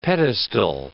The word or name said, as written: pedestal